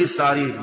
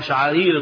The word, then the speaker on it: شعارير: عشان تيجي تقول لي ايه، طب هو مش عارف ينطقها شعارير النطق الصحيح؟